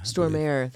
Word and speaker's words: Stormare: Yeah, even with I hope I'm pronouncing his last name right, but is it Peter Stormare? – Stormare. – Stormare, that's right.